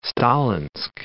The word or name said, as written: Stalinsk